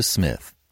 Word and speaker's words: Psmith: And now, Leave It to Smith. The P is Silent.